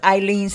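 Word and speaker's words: Ileane: My name is Eileen Smith, and I want to spell that for you. It's I-L-E-A-N-E.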